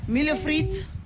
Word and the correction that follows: milho frito: Am I saying it right? – Close. Yeah, sure. – How do I say it? – Milho frito.